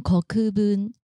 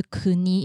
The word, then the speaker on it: 国分: 那国分的时候我念错了，国分应该念成 Kokubun。嗯，对，因为当时我念的是 Kuni，因为。国有两个发音，一个是 kuni，一个是 koku。那在这边，对，在这边他的姓呢是念 kokubun，这边做更正。